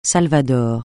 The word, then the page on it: Salvador: francais